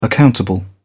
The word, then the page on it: accountable: British English